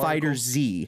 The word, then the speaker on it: FighterZ: FighterZ.